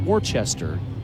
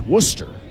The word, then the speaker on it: Worcester: It's not Worcester, it's Worcester.